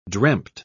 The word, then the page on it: dreamt: British English